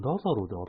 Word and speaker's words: LAZARUS: ラザロかな？ – ラザロ？ – ラザロかな？ – 聞いたことないんですけど。 – ラザロだったかな？ラザロだったかな？そうですね、今調べますね。 – 僕も調べます。 – あ、でもラザロで合ってる。